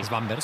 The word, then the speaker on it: Svanberg: Y por cierto, habría que mirar, pero yo no sé, Svanberg, no sé si decís, se dice así. – Svanberg. – Sí, Svanberg.